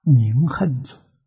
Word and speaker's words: 明行足: 第四个德号叫明行足，啊，古时候这个行呢，也读作恨，啊，明恨足，读作明，啊，行，名词，读恨呢是动词，啊，是行动，是动词。